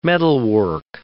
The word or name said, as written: metalwork